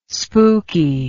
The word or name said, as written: spooky